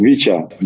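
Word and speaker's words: Khvicha: so it's Kavicha, Kavicha, Klavratskila. Is that right? Have I done okay? – Zvicha, it's Vicha.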